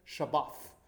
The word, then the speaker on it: Shabbat: Shabbat is the more Hebraic pronunciation.